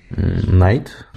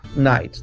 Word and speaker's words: Knight: Knight. Knight, да, пишется тоже через кей, просто это отпало.